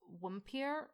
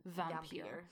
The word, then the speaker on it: Vampir: is from Austria and is named what we in English would pronounce as Wumpir or Wampir, but in German it would be Vampir.